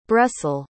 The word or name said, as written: bressal